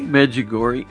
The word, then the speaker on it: Medjugorje: A friend of, how do you say it? – Medjugorje. – Medjugorje? Yep. Is that how it's said? – I think that's right.